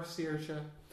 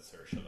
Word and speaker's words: Saoirse: She pronounces it Saoirse because, I mean, that's fine. But it comes from the Irish word Saoir, which is freedom. So Saoirse is how it should be.